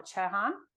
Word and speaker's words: Chohan: Ladies and gentlemen, this is Amar Chohan.